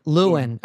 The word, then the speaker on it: Llewelyn: Luwain. – All right. I always have a hard time with his name for some reason. – Luwain.